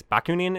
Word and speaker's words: Bakunin: Probably the second major character is Mikhail, I think it's Bakunin is how you say it.